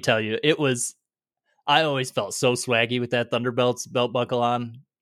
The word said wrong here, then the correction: Thundercats: Thundercats cat buckle.